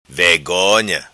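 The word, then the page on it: Vegonha: patua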